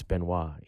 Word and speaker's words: Benoit: Which for a while I did call myself, but then when I had a relationship again with my father, he's like, no, it's Benoit, you know. – Okay, it's Benoit, you have to pronounce it Benoit, that's the right way.